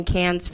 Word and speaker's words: Cannes: It was filmed in 2008, and within, I think, 6 months before it was filmed, premiered in Cannes Film Festival, June 2009. – It's French. You don't pronounce the S.